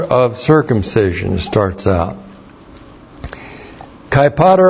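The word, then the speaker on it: Pater (πατήρ): The conjunction kai plus the accusative singular of pater plus the genitive singular of peritome. Kai is a continuative kai. It's correctly translated and. And then we have pater. P-A-T long E-R. Pater.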